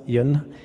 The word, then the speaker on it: Yon: On prononce ça comme ça, Yon.